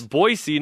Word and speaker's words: Boise: It's Boise, not yeah, Boise, Boise. No Z.